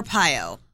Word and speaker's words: Arpaio: Arpaio, I think. – Joe yeah. – Arpaio.